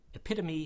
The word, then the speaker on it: epitome: Epitome has four syllables and rhymes with anatomy, though it has an E on the end, not a Y.